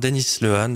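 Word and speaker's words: Dennis Lehane: Rivage Noir, Denis Lehan, j'ai décidé de le prononcer comme ça finalement.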